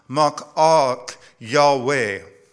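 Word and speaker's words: Mal'ak Yahweh: In the Hebrew, it's pronounced Ma'ak Yahweh.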